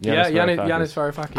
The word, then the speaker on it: Yanis Varoufakis: But we can move straight on there into probably the most interesting and consequential conversation of our trip, which was with Yanis Varoufakis. Am I saying that right? – Yanis Varoufakis.